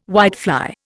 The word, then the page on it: whitefly: Indian